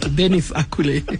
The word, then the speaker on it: Denis Ackulay: I think I've said it right, but I see he's also referred to as DNA. Which is it, DENIS? – yes, it's right, DENIS ACKULAY.